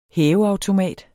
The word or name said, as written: hæveautomat